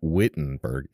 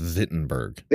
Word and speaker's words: Wittenberg: Yes, and you, and you must be able to pronounce it right. It's not Wittenberg, it's Wittenberg.